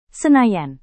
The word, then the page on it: senayan: indonesian